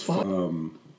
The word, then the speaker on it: pho: You taste the foe? The P-H-O, the foe? – I think it's pronounced foe. It does have a certain... – Food. – Like a comforting bowl of hot pho. That's right.